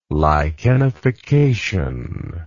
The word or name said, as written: lichenification